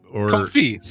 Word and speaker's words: confit: Well, you said feet or confit, C-O-N-F-I-T. – Oh, I thought you said feet, like their feet. – I think I might have pronounced the T. Confit, turkey confit.